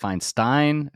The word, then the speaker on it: Feinstein: I also will say most people do not pronounce my name correctly, but you nailed it on the button, man. I'm like, most people are like Feinstein, man, right on the button.